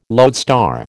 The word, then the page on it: lodestar: American English